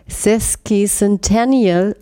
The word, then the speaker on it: sesquicentennial: Sesquicentennial wird das richtig ausgesprochen.